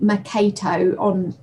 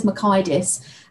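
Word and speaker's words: Mockaitis: It doesn't look like that. It looks like Pete Makato on, but he says McIdis.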